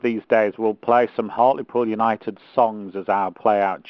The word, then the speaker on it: Hartlepool: Is it Hartlepool? – Hartlepool, that's right, yeah. – And I called it Hartlepool.